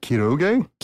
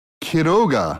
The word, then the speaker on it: Quiroga: Anyway, so today we are talking about Horacio Quiroga. – Horacio Quiroga. – That's how you say it if you're not from, you know, Northwest Arkansas.